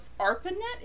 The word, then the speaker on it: ARPANET: Anyway, so the AI itself is supposed to be kind of born in the original internet of ARPANET, if I don't know if I'm saying that correctly. – Yeah, that's right.